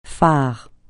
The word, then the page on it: farouchement: francais